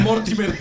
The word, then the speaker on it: Mortimer: Morterio, ¿cómo se dice? Mortimer, Mortimer. Mortimer.